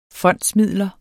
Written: fondsmidler

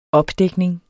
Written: opdækning

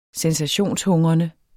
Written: sensationshungrende

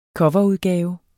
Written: coverudgave

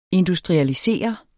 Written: industrialiseret

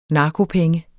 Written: narkopenge

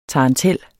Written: tarantel

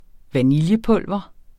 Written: vaniljepulver

